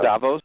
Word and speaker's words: Davos: You look at Davos, or I'm not quite sure how you pronounce the city in Switzerland when they just had their Davos.